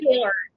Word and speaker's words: Kahler: That's K-A-H-L-E-R, and I'm not sure if I'm pronouncing her last name right. – Kahler.